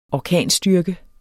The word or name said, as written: orkanstyrke